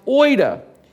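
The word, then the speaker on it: oida (οἶδα): But there's another word, it's pronounced oida.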